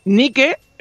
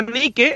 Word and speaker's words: Nike: porque Nike, eh, la diosa Nike, es la diosa de la fortuna, que es una diosa griega, y se llaman Nike por, por la diosa.